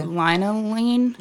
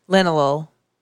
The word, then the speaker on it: linalool: Linalool? – Limonene? – No. – Linalool.